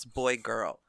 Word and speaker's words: boy x girl: I first started to get into yaoi when I started getting bored of boy x girl, which I'm informed anime fans pronounce boy girl.